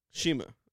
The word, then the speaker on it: Cima: Okay, so how do you pronounce the guy that fought Kenny Omega? Is it Shima? – Shima. – C-I-M-A? – C-I-M-A, Shima.